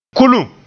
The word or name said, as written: kulum